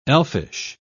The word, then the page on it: elfish: British English